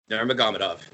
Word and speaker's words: Nurmagomedov: I just call him Nurmagomedov. – Nurmagomedov, yes.